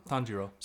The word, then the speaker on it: Tanjiro: Because how do you say his name? – Tanjiro? – Tanjiro? – Tanjiro.